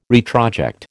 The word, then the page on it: retroject: American English